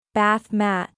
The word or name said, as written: Bath mat